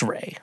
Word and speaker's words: Ralph: So I initially thought when Voldemort, our head chef, Ray Fiennes, which it's spelled like Ralph, but he's like, that's Ray.